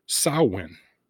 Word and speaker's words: Samhain: Now, Samhain is not pronounced, or it's not spelled anything like it's pronounced. Samhain is actually spelled S as in Sam, A-M-H-A-I-N, Samhain. Now, that's a correct pronunciation of it because I looked it up.